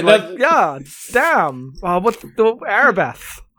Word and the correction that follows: Elbereth: Yeah, Elbereth.